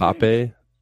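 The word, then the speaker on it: Mbappé: Is that how you pronounce yep. it? Mbappe, PSG, mm-hmm. Paris, Paris team. Yes, that's correct.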